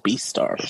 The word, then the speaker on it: Beastars: Yeah, like Beast. – Oh, Beastars. – Stars.